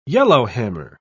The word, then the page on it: yellowhammer: British English